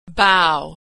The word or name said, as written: bough